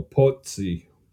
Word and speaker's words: Pozzi: Like pizza, but long O. Pozzi.